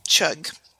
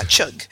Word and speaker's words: Chug: Chug. Yeah, Chug. It's like the sound in good, like Chug.